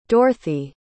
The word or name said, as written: dorthy